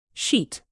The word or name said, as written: sheet